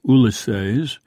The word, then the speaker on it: Ulysses: Shortly after Ulysses was published, which, by the way, Joyce pronounced Ulysses, Nora remarked to a fan of his, "I've always told him he should give up writing and take up singing."